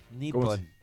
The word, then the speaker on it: Nippon: Y me está diciendo que estás pronunciando mal la palabra. – J-Pon. – ¿Cómo se escribe? – Nippon.